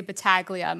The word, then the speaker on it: Battaglia: I was going to say he changes a lot, but I can go on a rant about this because my last name is pronounced, I think, four different ways. – But do you pronounce it? Family. – Oh, interesting. But do you pronounce it four different ways or do you pronounce it Battaglia? – I always say Battaglia. My sister has tried to switch and it doesn't work on the East Coast. Actually, I will say if you're like in New York, people will say it correctly. We say it Americanized.